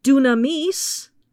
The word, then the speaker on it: dynamis: Dunamis, which is pronounced that way despite being transliterated into English as D-Y-N-A-M-I-S,